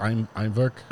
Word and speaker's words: Eimverk: It's a handcrafted and produced from 100% Icelandic barley by Imvik Distillery. Am I saying it right? – It's Imvik. – Imvik. – E-I-M-V-E-R-K.